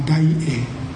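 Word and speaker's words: Daie: But Dai E, let me use Japanese pronunciation, because I really cannot pronounce Chinese correctly. So let me call Da Hui as Dai E.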